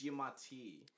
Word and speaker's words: Giamatti: It's Giamatti.